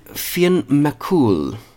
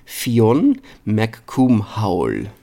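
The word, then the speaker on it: Fionn mac Cumhaill: En Finn McCool. – Aha. – Eh, vaak uitgesproken als Finn McCool. Nou, dat klinkt natuurlijk heel cool, maar het is eigenlijk Fionn McCoomhowl.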